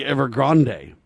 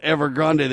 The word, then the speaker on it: Evergrande: It's a Chinese company, ladies and gentlemen, a huge real estate company that builds real estate, but it's Evergrande, E-V-E-R-G-R-A-N-D-E, so it might be Evergrande. I'm not sure how they pronounce it. It's kind of like Ariana Grande. It's got an E on the end, right? So they call it Evergrande.